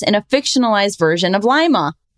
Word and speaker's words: Lima: Next up, we have Robin, correction, Lima, Ohio. Oh, shit. Oh, it's pronounced with a long I, as in lima bean, not as in Lima, Peru.